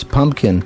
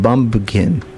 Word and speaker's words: pumpkin: Well, when I first was learning English, I couldn't pronounce pumpkin. I pronounced it bumpkin. There is no P in Arabic.